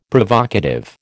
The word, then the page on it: Provocative: American English